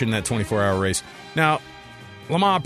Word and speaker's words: Le Mans: That's how you say it, by the way. It's Le Mans. It's not Les Mans. You'll get in trouble for saying it.